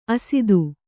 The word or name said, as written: ಅಸಾಧು